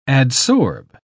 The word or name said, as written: adsorb